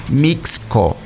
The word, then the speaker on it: Mixco: Mixco, hay que pronunciar bien, Mixco, ¿verdad? La vez pasada estaba escuchando a alguien, Alex, que se comió la X.